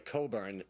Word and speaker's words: Cockburn: Her birth name was Olivia Coburn, that's spelled Cockburn.